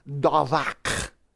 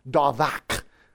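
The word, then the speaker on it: davak (דָּבַק): But behind our English word cleave, we find the Hebrew word davak. That's how it's pronounced, davak.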